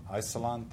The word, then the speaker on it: Isilon: But thanks to our sponsors, IX Systems, Usenix, NetApp, Google, the FreeBSD Foundation, Isilon Systems, is that how you pronounce that? – Isilon, thank you.